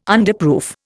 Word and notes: Underproof: Indian

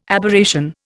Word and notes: Aberration: Indian

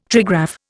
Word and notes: trigraph: Indian

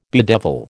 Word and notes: bedevil: American English

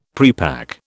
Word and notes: prepack: British English

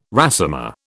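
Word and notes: résumé: British English

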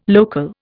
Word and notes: local: Indian

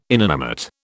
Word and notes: inanimate: British English

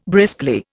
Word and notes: briskly: Indian

